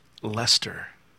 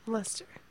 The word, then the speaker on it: Leicester: And what I pronounced was Leicester or something. I forget exactly how I pronounced it. Apparently, even though it's spelled that way, us Yanks, apparently we murder. Because I checked with my buddy, Dark Flib, over there. And I said, did I, like, butcher? Because apparently it's pronounced Leicester.